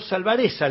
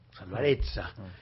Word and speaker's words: Salvarezza: Le mandamos un abrazo y muchas gracias por esta entrevista, Salvarecha. – No, por favor, a ustedes. – Era Roberto Salvarecha, le dicen muchos, bueno, pero hay que pronunciarlo como corresponde, ¿eh? Salvarezza,